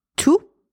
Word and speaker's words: tous: T-O-U-S is pronounced tout when it is followed by a noun. And my little trick, if you can swap T-O-U-S with every,